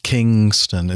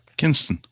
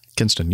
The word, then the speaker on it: Kinston: But Spartansburg is not right, and Kingston is not right either, unless you're going to Jamaica. – Kinston. – Kinston. You got it.